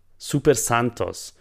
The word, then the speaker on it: Super Santos: Super Santos wird in diesen wahnsinnig verbreiteten Sätzen ausgesprochen wie ein einziges Wort.